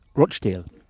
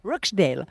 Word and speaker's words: Rochdale: Vous qui êtes originaire du Grand Manchester, Rochdale, on prononce ? – Rochdale. – Rochdale.